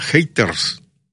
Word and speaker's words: haters: Dile a Lady Eventos que se le dice haters, se pronuncia haters.